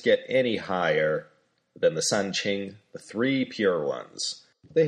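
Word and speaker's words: San Qing (三清): In Chinese, they say all that in two syllables. They're known as the San Qing. San, first tone, means three. Qing, well, among many things, means pure.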